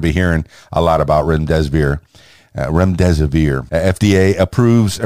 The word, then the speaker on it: remdesivir: Number one, the FDA approves emergency use of remdesivir. Now, I hope I'm pronouncing that right. Remdesivir, R-E-M-D-E-S-I-V-I-R.